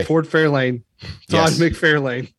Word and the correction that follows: Todd McFarlane: Look, it's spelled A-N-E. So it's Todd McFarlane.